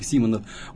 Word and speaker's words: Симонов: Он четко убрал фамилию, букву из фамилии букву А, поэтому произносится как Симонов.